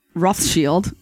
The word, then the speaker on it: Rothschild: But we're American, so we're like Rothschild. But Rothschild is how it's actually pronounced, which means red shield because they lived in the building with the red shield.